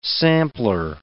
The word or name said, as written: sampler